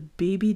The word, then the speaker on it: Bebbi: I think it's meant to be pronounced the baby diet, but I think it's actually spelled like B-E-B-B-I.